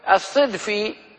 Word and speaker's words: الصدفي: القاضي الحافظ أبو علي رحمه الله هو الصدفي أو ابن سكرة وابن حيون وابن فيره، والصدفي بفتح الدال، ولا يزال كما حدثني بعض أهل العلم في بلاد المغرب ينطقونه الصدفي بكسر الصاد، نعم.